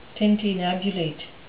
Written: tintinnabulate

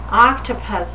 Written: octopus

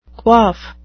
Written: quaff

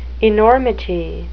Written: enormity

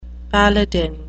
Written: paladin

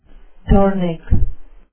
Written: dornick

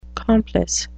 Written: complice

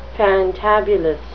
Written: fantabulous

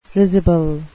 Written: risible

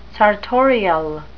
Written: sartorial

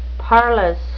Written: parlous

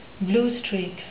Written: blue streak

